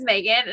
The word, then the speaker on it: Maghon: Like, my name is Megan, and my mom chose to spell it M-A-G-H-O-N, which is not how you spell Megan.